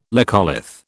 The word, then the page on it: laccolith: British English